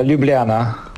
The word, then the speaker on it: Ljubljana: Na Lublanie? Miękko wymawiamy? – No ja mówię, nie wiem, ze słoweńska, Ljubljana.